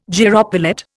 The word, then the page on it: gyropilot: Indian